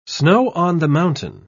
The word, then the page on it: snow-on-the-mountain: British English